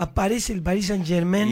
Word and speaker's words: Saint-Germain: Exacto. Saint-Germain. Pronuncie bien, querido.